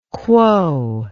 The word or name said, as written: quo